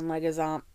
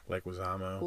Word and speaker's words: Leguizamo: And John Leguizamo. How do you say it? – Leguizamo.